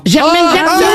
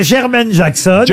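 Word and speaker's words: Jermaine Jackson: Germaine. Vous l'avez mal prononcé. – Si vous avez dit Germaine, c'était moins drôle. – En plus, je le connais, il n'aime pas du tout qu'on l'appelle Germaine. – C'est Germaine Jackson.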